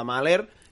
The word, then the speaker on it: Mahler: de Mahler que, si ho he pronunciat bé, sí, em, sí llavors vale.